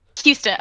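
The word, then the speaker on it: Huston: Houston? I can't even pronounce like Texas. – Houston, okay, that'll help me remember.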